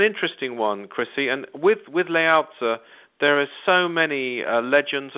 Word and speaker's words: Lao Tzu: So the next cosmic avatar I was going to mention is Lao Tzu, pronounced Lao Tzu, I'm reliably informed, or something nearer to that than what I said before.